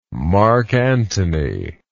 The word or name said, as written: Mark Antony